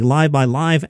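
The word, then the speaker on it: LiveXLive: That's a show I host on the Live by Live app. The Live by Live app is free. It is spelled Live X Live, but you can get the app for free.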